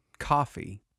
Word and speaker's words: Ko-fi: I keep forgetting we do have, is it pronounced Kofi? – Coffee? I think it's coffee. – C-O-F-I. Oh. We don't have a Patreon. We do have a Kofi. Coffee? – I feel Kofi like coffee. It's also K-O-F-I, not C-O. It's K-O-F-I.